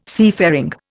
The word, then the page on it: seafaring: Indian